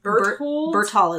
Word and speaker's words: Berthold: So there's Berthold is how they say it throughout the episode.